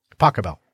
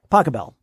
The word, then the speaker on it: Pachelbel: I did it phonetically, but Pachelbel's. – Pachelbel. – Pachelbel.